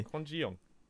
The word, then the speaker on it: Kwon Ji-yong: K-W-O-N. G-I. – Uh-huh. – No, not G-I. J-I. – J-I. – Y-O-N-G. – Sure, that sounds perfect pronunciation to me. – Yeah, Kwon Ji-yong.